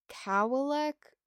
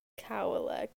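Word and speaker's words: Kawalek: I want to say I'm saying that right, but I don't think I am. – Mr. Cowlick. Got it. – No, like... – I'm sorry. – No, it's K-A-W-A-L-E-K. – Cowlick. Okay. Okay.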